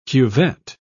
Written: cuvette

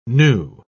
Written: gnu

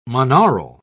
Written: monaural

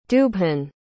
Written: dubhan

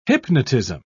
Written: hypnotism

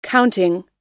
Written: counting

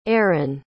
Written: errin

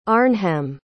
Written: Arnhem

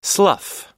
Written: slough

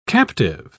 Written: captive

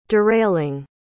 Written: derailing

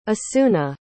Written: asuna

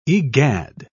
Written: egad

